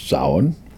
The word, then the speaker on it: Samhain: But the point is that because we're so close to Samhain, Samhain, right? Pronounced Samhain. One of the peculiarities of the Celtic language, we're very close, and it's going to be, like I said, it's a solar holiday.